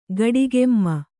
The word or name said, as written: ಗಡಿಗೆಮ್ಮ